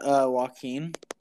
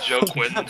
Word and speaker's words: Joaquin: Is that Joaquin? – Yes. – Yeah. – How'd you pronounce it last podcast? – I'm not going to try to do that again. – I said Joe Quinn. – Joe Quinn. – On purpose you said that? – I didn't know how to say it.